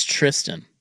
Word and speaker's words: Thurston: And I found the pronunciation of Thurston Lawrence. – Yes. – It's Tristan. – Yeah, which is a little odd, but... – Yeah, the H is silent. It's Tristan Lawrence.